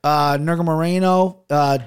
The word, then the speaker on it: Nurmagomedov: Nurmagomedov versus Kennedy and Zekchukwu.